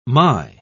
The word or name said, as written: my